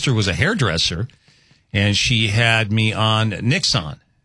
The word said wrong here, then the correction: Nioxin: Nixon? Is that right? Did you spell that right? Naya. Okay. Nioxin.